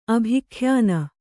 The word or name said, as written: ಅಭಿಖ್ಯಾನ